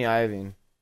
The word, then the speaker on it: Iovine: Yeah, Jimmy Iovine.